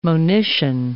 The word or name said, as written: monition